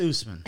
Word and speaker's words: Usman: U-S-M-A-N.